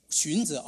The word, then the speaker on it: 荀子: 中国古人有个思想家叫孙子，孙啊，不是兵法那个孙，是一个荀子啊，荀子就五旬节那个荀子是吧？是那个荀子是吧？怎么读？荀子。好，他在前面是有孟子，还有孔子，他们提倡就是道德教育，改变人的性，人社会改变人性。但是荀子呢，他说人性本恶，没错。